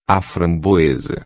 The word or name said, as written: framboesa